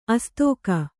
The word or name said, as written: ಆಸ್ತಿಕ